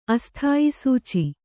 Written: ಅಸ್ಥಾಯಿಸೂಚಿ

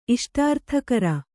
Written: ಇಷ್ಟಾರ್ಥಕರ

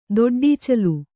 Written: ದೊಡ್ಡಚಿಲ್ಲ

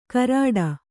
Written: ಕರಾಡ

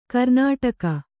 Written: ಕರ್ಣಾಟಕ

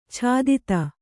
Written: ಛಾದಿತ

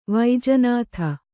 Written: ವೈಜನಾಥ

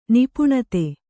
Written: ನಿಪುಣತೆ

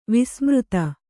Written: ವಸುಮದ

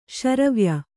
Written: ಶ್ರಾವ್ಯ

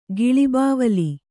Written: ಗಿಳಿಬಾವಲಿ

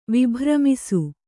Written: ವಿಭ್ರಮಿಸು

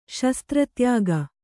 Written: ಶಸ್ತ್ರತ್ಯಾಗ

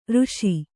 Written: ಋಷಿ

